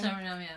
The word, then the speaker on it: Suriname: From Suriname. – Suriname, yeah.